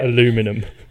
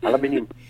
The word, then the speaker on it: aluminium: Just say Alu in front of that. Aluminium.